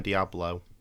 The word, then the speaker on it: Deablo: His name is spelled differently. It's Deablo, but still. – Oh, okay. – It's pronounced Divine Diablo.